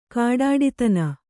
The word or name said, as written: ಖಡ್ಡತನ